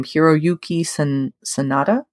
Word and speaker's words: Hiroyuki Sanada: You got Zazie Beetz, Sandra Bullock, I'm going to butcher his name, Hiroyuki Sanada.